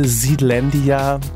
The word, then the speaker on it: Zealandia: Sag mal, Zealandia, ne? – Ich glaube ja, also es ist die amerikanische Ausspracheweise Zealandia. Kannst du auch vielleicht Zealandia sagen dann auf Deutsch? – Auf jeden Fall irgendwas, eins von den beiden.